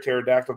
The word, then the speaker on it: pterodactyl: But I also like to let you know that did you know you can't hear a pterodactyl going to the bathroom because the P is silent? – You know what?